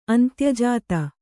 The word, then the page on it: ಅಂತ್ಯಜಾತ: kannada